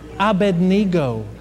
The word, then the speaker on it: Abednego: No, it's Abednego. What do you say? To bed we go? Is that what you said? Abednego? There's no N. You look at it. Abednego.